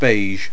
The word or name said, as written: beige